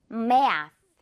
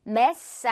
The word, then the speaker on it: math: 역시 메롱 발음 그리고 윗니 아랫니가 잡아주면 돼요. Math. Math. Mess 아니고요. S 이렇게 하면 안 돼요. 혀가 나와야죠.